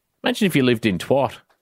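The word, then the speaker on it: Twatt: A Scottish council has issued a warning after toxic scum was found near Twat.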